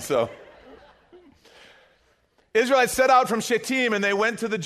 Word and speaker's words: Shittim: It says, Early in the morning, Joshua and all the Israelites set out from, it's actually pronounced Shittim.